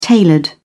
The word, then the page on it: tailored: British English